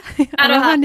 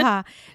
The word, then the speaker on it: Aroha: So you said, I probably can't pronounce it, Aroha? – Aroha Knits? – Yeah. – Aroha. So it's A-R-O-H-A Knits, yes.